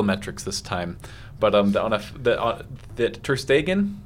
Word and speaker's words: Ter Stegen: And right now, I think it's I can never pronounce Ter Stegen. – Yeah, Ter Stegen.